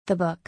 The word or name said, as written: The book